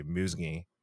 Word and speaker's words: Musngi: And then last name is M-U-S-N-G-I. And you pronounce that like hanging, and you take the and you say musgi.